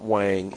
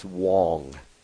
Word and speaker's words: Huang: I'm told I pronounce it wrong. We've been pronouncing it wrong, Barry. It's not Wang. It's Huang. It's Jensen Huang.